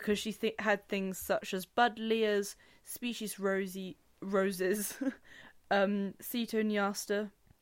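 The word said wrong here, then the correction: cotoneasters: I read my notes so badly then, and I said to myself the other day, you must remember to say that correctly. Cotoniasters. I didn't mean cetoniaster.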